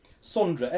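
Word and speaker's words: Sondre Lerche: This is the artist Sondra Lerche, which may not be how you say his name. Sondra Lerche. – Is it a she? – I don't know. No, it's a fella. It's a fella. Sondra. S-O-N-D-R-E. – Sondra.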